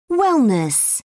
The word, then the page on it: wellness: British English